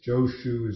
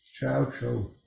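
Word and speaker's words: Chaozhou (趙州): I can't pronounce the Chinese way of pronouncing it. Joshu is the Japanese way of pronouncing the name. The Chinese way is Chaozhou, but that's off because I can't do the tonal.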